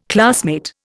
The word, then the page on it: classmate: Indian